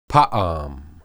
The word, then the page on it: paam: Hebrew